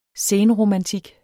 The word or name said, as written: senromantik